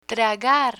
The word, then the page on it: tragar: spanish